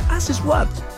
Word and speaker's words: As It Was: Sí, así se pronuncia, as it was.